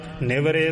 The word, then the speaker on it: निवारे: पहला पाठ निवारे ते दूसरा निवरे है। दोवां पाठां नूं जिवें ज्ञानी जी ने विशेष ध्यान दे के उच्चारण कीता है, काम क्रोध लोभ मोह निवारे निवरे सगल बैराई, दोवां नूं ध्यान दे नाल उच्चारण करना जी।